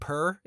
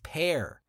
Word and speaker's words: Per: That's not how you say his name. His name is Pear. – How do you spell it? – It is spelled P-E-R, but it is a common name in Scandinavia, and it's not a nickname. It's a short version of Peter, according to Pear.